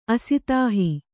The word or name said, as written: ಅಸಿತಾಹಿ